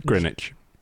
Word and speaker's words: Greenwich: Greenwich. – But it has two E's. Greenwich. – The W is silent.